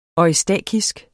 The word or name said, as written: eustakisk